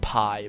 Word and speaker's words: Pai: we pronounced it Pai. It was P-A-I.